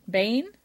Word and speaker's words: Baen: Bayen Publishing, B-A-E-N. They actually publish science fiction works, and they're still taking submission. Bane? – Yeah. – Am I saying it wrong?